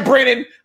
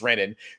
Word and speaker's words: Renan: And in the first round, we saw Michaela, M-I-C-H-A-E-L-A, right? My daughter is spelled M-I-K-A-Y-L-A, against Renan, which is, I thought, is that Brennan? I want to bring up a Bolts and Hoes reference, but it's just saying, no, it's Renan.